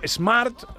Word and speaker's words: SMART: Bueno, vamos con el método SMART. – Digo. Eh, método SMART. – Muy bien. ¿Cómo es? – Smart. – Smart. – Es no pronunciar la E al principio. it's SMART. Smart.